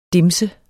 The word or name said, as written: dimse